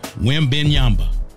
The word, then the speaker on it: Wembanyama: But I got to go with the 7'4" T-Mac, the 7'4" Unicorn, the 7'4" Tracy McGrady down in San Antonio, Victor Wembanyama. I'm glad I finally got his name right, because I've been butchering his name all offseason in summer league. Victor Wembanyama.